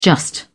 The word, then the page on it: just: British English